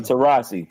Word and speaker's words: Taurasi: It's Diana Taurasi.